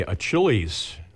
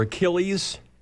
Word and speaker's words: Achilles: Okay, Achilles. Or is it a hard C or a soft C? – I guess I make it a K, but... – Okay. Achilles or Achilles, he waits for you, a great big dog.